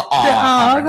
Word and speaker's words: AWE: I actually have to look up whether you pronounce it AWE or AWE. I didn't want to sound stupid on air. – It's the AWE conference. Alex is down at AWE.